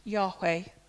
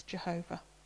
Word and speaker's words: Yahweh: Probably pronounced Yahweh originally, but sometimes we hear it as Jehovah.